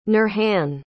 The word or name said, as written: nurhan